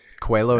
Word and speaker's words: Coelho: Coelho, is that how you say it? – It's pretty, yeah. I learned that from the guy who produced the audiobook for it, actually. He went out and found it because it's a Portuguese name.